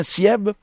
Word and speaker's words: Sieb: Est-ce que je prononce bien votre nom? C'est bien Sieb? – Sieb. – Sieb. S-I-E-B, c'est ça? – Oui, oui.